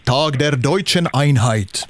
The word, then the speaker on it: Tag der Deutschen Einheit: Ja, om vi säger det riktigt officiellt på tyska så är det Tag der Deutschen Einheit. – Ja, det är så. Ja, så är det. Fint uttal där.